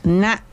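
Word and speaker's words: na': La siguiente palabra es na, que se escribe con la letra N.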